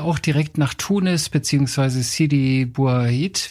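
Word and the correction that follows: Sidi Bou Said: wie spricht man das aus? Sidi Boussaid, ja genau. Sidi Boussaid, genau.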